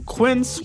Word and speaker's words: Quince: Today Explained support today comes from Quince, which rhymes with since but is spelt with a Q-U.